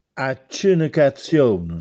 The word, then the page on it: Accenecaziòne: Castellanetano